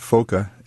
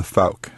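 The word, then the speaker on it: Fouke: Oh, and in Holland, they pronounce it Foka. And for some reason, when my ancestors came over and settled here in the States, they changed it to Fauk.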